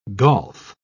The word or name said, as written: golf